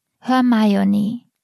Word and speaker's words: Hermione: Im vierten Band schmuggelt die Autorin ein, wie man Hermione richtig aussprechen soll. Hermione. Mit der englischen Betonung der Silben. Wie das Versmaß des Wintermärchen verrät, wird der Name Hermione auch dort so ausgesprochen. Hermione mit Akzent auf der zweiten und letzten Silbe.